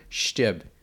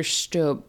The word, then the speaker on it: Stubbe: This man was Peter Stib. – God damn it. I knew you were going to do that. So the German pronunciation is Peter Stub.